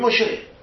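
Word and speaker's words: משה: نمیگیم میم شین ه میگیم مشه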